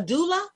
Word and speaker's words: doula: And so she was like, oh, you know, she's also a doula. Am I saying that right? – Mm-hmm. You're saying it right.